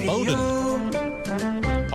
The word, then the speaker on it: Bowdoin: It's pronounced Bowdoin.